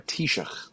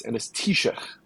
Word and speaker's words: Taoiseach: But yeah, sorry, Taoiseach, that's how you say it. That was a whole thing in Seth Meyers's corrections, which is how do you say the name of the Irish Prime Minister, right? And it's Taoiseach. There you go. But it's spelled Taoiseach.